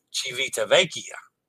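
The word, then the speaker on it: Civitavecchia: Did I say that wrong again? Civita Vecchia. Civita Vecchia. Civita Vecchia. Sorry. Civita. Oh, Civita Vecchia. Civita Vecchia. I had two syllables wrong.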